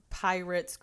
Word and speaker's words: Pierates: Pirates Cruise, and it's spelled weird. It's spelled like P-I-E-R-A-T-E-S.